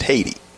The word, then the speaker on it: Hayti: But if you look at the French map, if you look at the map, old maps, you will see Haiti spelled H-A-Y-T-I. Mm-hmm. Of course, they pronounce it Haiti.